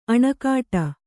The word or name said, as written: ಅಣೆಕಟ್ಟೆ